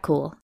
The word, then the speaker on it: Kuhl: I'm Kat Cool, and I can be found on Twitter at WolvesAreCool, with cool spelled like my last name, K-U-H-L, or at @CampaignPod for the show.